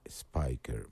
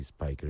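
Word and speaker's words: Spicher: Es el disco de los Buddies, Buddy Emmons y Buddy Speicher, aunque en realidad se pronuncia Spiker, Buddy Spiker,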